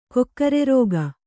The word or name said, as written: ಕೊಕ್ಕರೆರೋಗ